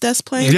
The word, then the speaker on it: Des Plaines: Also, shout out to everybody for letting us know that Des Plaines is pronounced Des Plaines.